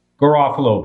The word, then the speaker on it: Garofalo: Did I say that right? – Garofalo.